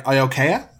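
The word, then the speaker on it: Iaukea: Next, we get the second appearance of Prince, I have to say it right, Iokea. I think I got it right this time. – Yeah, you got it.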